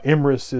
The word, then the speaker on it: Emrys: Uh, Emrys is the Welsh pronunciation of Ambrosius.